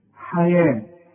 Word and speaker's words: حياة: حياة هي مكتوبة بالواو، تكتب في المصحف بالواو. لما أجي أقول لك اكتب الحياة هتكتبها ي وألف الحياة، إنما كتابة المصحف خلاف الكتابة العادية دي مكتوبة بالواو لكن تنطقها بالألف، ولذلك رسم لك ألف فوق الواو حياة.